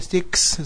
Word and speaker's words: Styx: Pronunciado como dice Styx.